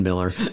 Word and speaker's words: Mueller: And uh, for those who are unfamiliar with the, the Miller neighborhood, and, and yes, it, it is pronounced both ways, Mueller and Miller.